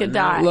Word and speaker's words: Gadot: That's French, and she's from Israel. – Good. So if you pronounce it escargot, then you can say Gadot.